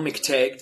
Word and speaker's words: McTague: Today I am joined by a new friend who I connected with via Twitter, Darrell McTague.